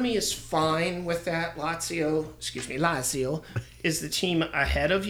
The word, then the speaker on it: Lazio: So they lost 2-1 to Lazio. And it was one of those games. – Say it right. Lazio.